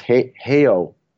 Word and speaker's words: Ccello: And what we announced today was that it's pronounced Heo Prospect. It's cello is how it'd be spelled, C-C-E-L-L-O, but it's pronounced Heo, which makes it difficult for us, but that's the name of the prospect.